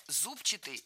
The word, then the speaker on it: зубчатый: Мы услышали прилагательное «зубчатый» с ударением на первом слоге. И это правильный вариант. Но правильным он стал совсем недавно. Раньше нужно было ударять второй слог «зубчатый». Однако в новый словарь, рекомендованный Министерством просвещения, внесены уже два варианта – «зубчатый» и «зубчатый».